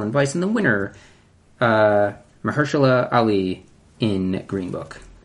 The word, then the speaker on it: Mahershala: Mahershala Ali in Green Book, Adam Driver in Black Klansman, Sam Elliott in A Star Is Born, Richard E.